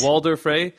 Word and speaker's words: Walder Frey: Did I do it right just now, Joanna? Right? – Perfect. – Walder Frey.